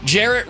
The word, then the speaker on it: Jarrett: Because Jarrett rhymes with ferret.